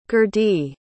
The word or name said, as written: gerdie